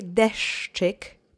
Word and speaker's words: deszczyk: Now let's say deszczyk together, slowly, three times. Accent is on the first syllable.